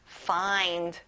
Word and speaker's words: find: Find. 끝에 d까지 정확하게 하셔야 돼요. Find.